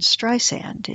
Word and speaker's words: Streisand: She found the spotlight warm and comforting, quickly lopped off that second A from her first name, and reminds us now that the second S in Streisand is soft, telephoning Tim Cook to get the pronunciation corrected on Siri.